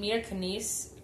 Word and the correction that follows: Mila Kunis: Mila Kunis.